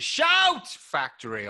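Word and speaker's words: Shout! Factory: I wasn't sure whether I should pronounce it as Shout Factory or not. – I think so. There is an exclamation mark. So, yeah, you're right.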